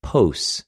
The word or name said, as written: πως